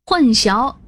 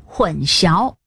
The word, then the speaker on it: 混淆: 混淆意思是使界限模糊，混淆的混跟混乱的混同字，读第四声，不能读成混淆，也别读成混淆或混淆。混淆的淆右边确实是菜肴的肴，但左边还有三点水，读淆。